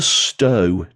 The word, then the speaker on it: Stow: It's spelled S-T-O-W. There is a Stow down in England, but the one in Scotland, this one, is actually pronounced Stow.